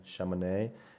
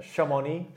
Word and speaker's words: Chamonix: I think this event out here in Chamonix has, I'm not sure if that's how you say it, but Chamonix. I was calling it Chamonix up until day three.